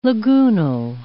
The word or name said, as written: lagoonal